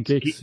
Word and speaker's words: Guix: G-U-I-X. – Oh, Geeks. Yeah. Pronounced Geeks.